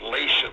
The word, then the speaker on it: Latium: The Romans, of course, spoke Latin, and they lived in a little bitty area that today we call, well, we pronounce it as Latium, L-A-T-I-U-M, Latium, mm-hmm. in English, modern-day Lazio, L-A-Z-I-O.